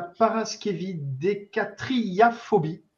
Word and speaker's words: paraskevidécatriaphobie: Alors, déjà, ce mot, ce mot, quel est-il C'est un mot en neuf syllabes qui décrit la phobie des vendredis 13. Pour le coup, on est vendredi 13 à 13h. Ce mot, ce mot, c'est la paraskevidécatriaphobie.